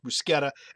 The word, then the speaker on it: bruschetta: I think that now that I know that bruschetta is pronounced bruschetta, and I should have known it knowing languages, but I never thought of it, I will say bruschetta.